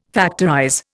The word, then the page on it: factorize: Indian